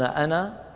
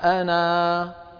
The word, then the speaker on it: أنا: أنا تحدثنا عنها في الدورات الماضية، ونذكر أن أنا في القرآن كله على رواية حفص عن عاصم ألفها محذوفة وصلا ثابتة وقفا، إذا وصلنا نقول أنا بشر، أنا بشر، يعني نقول نذكر ننطق فتحة النون وننتقل مباشرة إلى الكلمة التي بعدها، لكن إذا وقفنا نقول قل إنما أنا، أنا حركتين مدا طبيعية.